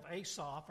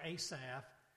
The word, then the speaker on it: Asaph: Asaph or Asaph, depending on which way you want to pronounce it, was used of God to write this particular psalm as well as some others.